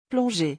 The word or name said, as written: plonger